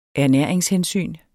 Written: ernæringshensyn

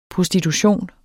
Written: prostitution

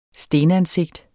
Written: STENANSIGTER

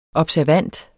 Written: observant

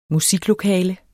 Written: musiklokale

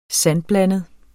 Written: sandblandet

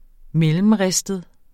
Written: mellemristet

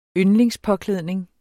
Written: yndlingspåklædning

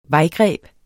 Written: vejgreb

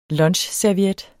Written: lunchserviet

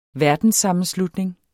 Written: verdenssammenslutning

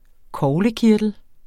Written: corpus

